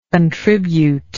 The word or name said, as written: contributable